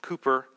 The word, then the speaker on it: Cowper: His last name is spelled Cowper, C-O-W-P-E-R, but his name is pronounced Cooper.